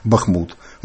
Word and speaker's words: Бахмут: Я знаю, у вас є поезія, за допомогою якої ви боретеся за правильний наголос в назві нашого міста. – Ну, борьба – это сложно, громко сказано. Но, по крайней мере, вот мои киевские друзья, они уже все безошибочно говорят «Бахмут».